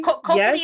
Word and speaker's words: Copley: Copley? – Copley is how you say the last name, I believe.